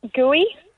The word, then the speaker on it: Guy: Gooey, but it's spelt G-U-Y. – Gooey. – G-U-Y. – So it's not guy. It's not guy. It's gooey.